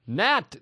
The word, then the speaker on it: gnat: Gnat. G-N-A-T. – Uh-huh. – That's strange, isn't it? – Yeah. What are you doing with these silent letters? – Same with gnaw.